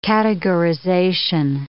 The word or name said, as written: categorization